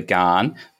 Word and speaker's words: Ghan: And I pronounced it the Gan. I'm sorry, it's the Ghan. I wasn't sure how to pronounce it, so I will correct myself going forward. – I go up and down, and everyone in Australia pronounces it the Ghan, but um, it's technically a shortened version of the Afghan Express was the original name. So Ghan is is how you're supposed to do it.